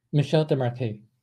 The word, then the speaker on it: Michel Desmarquets: Michel Desmarquets.